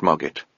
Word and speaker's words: Mogget: "The kings and queens of the Old Kingdom," pronounced Mogget, after some difficulty.